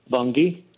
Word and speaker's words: Bunge: Would it be safe to invest with Bunge? – Bunge, first of all, how great it is that you pronounce it correctly.